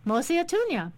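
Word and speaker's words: Mosi-oa-Tunya: Mosi Atunya.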